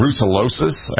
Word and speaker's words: brucellosis: They carry something called brucellosis, a bacterial infection. Is that how you pronounce it? – They do, exactly.